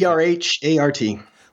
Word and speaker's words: Erhart: Erhart.